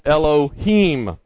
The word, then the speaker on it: Elohim: This word God that is translated there comes from the plural of the word God and is pronounced Elohim.